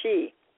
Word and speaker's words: Sidhe: F-A-E-T-H-F-I-A-H-A, pronounced Fifoa, or cloak of concealment. As time passed, it became known as the Sidhe, or She, Irish's folk, fairy folk.